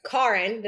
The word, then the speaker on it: Karin: Hello, everybody, and welcome back to another episode of the Messy Middle Podcast we already had Dr. Karin.